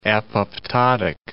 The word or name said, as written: apoptotic